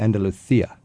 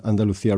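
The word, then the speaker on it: Andalucía: And in Castilian dialect, it would be Andalusia. – Mm-hmm. – Barcelona. – Yeah, it's a different way of pronouncing the Buenos días. – Buenos días. – Okay, so there's these differences. – Yeah. – That's good to know, because I thought Andalusia was wrong, but it's only the Madrid way to pronounce it, Andalusia.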